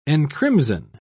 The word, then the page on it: encrimson: British English